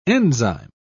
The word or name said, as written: enzyme